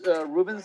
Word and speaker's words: Ruben: My name is Ruben Sevilla. It's you need to spell it? – Sure. – It's R-U-B-E-N, and last name is S-E-V, as in Venezuela, I-L-L-A.